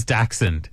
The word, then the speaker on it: Dachshund: It absolutely is Dachshund. – Are you sure? – Yeah, because that's just the proper way to say it. You can't win this one, Tyler. Because why? – You're wrong. It's... What do you call it? – Dachshund. – It's not like Dash DoorDash.